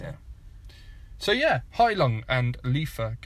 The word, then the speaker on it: Lifa: And the album's called Lifa, or Lifa. It's spelled L-I-F-A, and Heilung is spelled H-E-I-L-U-N-G.